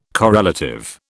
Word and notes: correlative: British English

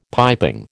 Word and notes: piping: American English

